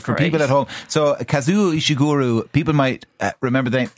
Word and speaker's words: Kazuo Ishiguro: So I had to Google how to pronounce his name properly over the weekend. – Okay. – So it's Kazuo Ishiguro.